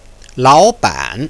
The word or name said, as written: laoban